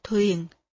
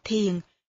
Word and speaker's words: thiền: Chữ thiền có một số người phát âm là thuyền. Hồi còn là chú tiểu, tôi cũng đọc là thuyền na. Nhưng sau này, tôi nghĩ chúng ta nên thống nhất cách phát âm là thiền để khỏi trùng với chữ thuyền là chiếc đò.